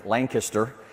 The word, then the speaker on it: Lancaster: It's L-A-N-G with the accent, Lang, K-I-S-S, kiss, then tur, Lancaster.